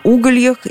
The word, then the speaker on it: угольях: Здесь можно выбирать, что делать – сидеть как на угольях или сидеть как на углях. Ударение в любом случае на «у».